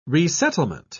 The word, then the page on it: resettlement: British English